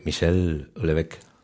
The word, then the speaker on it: Michel Houellebecq: ni más ni menos que Michel Houellebecq.